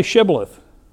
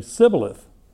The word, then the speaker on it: Shibboleth: They say, "Oh, you're not an Ephraimite, then say Shibboleth." And if you're an Ephraimite, apparently you've had trouble pronouncing that sound, and they would say Sibboleth.